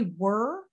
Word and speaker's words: Wuhrer: So Tori, I know we're jumping ahead, but Ariel was played by, and she's kind of famous, Carrie Wuerer. Is that how you say it? W-U-H-R-E-R. Yeah.